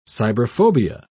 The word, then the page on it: cyberphobia: British English